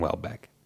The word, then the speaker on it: Houellebecq: And that's the way I'm going to say his name. I've watched on YouTube and people pronounce it fucking like 10 different ways. I'm saying Houellebecq, so pardon my French.